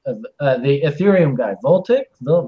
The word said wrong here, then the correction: Vitalik: Voltic, Vitalik, Vitalik, right?